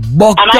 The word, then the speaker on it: bucked up: Bucked up. Am I saying it right? – Yes, bucked up.